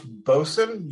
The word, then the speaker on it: boatswain: It's pronounced bosun. You would know that if either your stepfather or father-in-law were a Navy man,